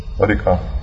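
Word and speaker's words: Rijeka: Rieka. – Rieka. – Second stress syllable.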